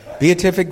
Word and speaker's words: beatific: How does it relate to, and I haven't checked the pronunciation of this with Chuck, the beatific vision? Beatific? Is there, okay, thanks, I'll have to fix that.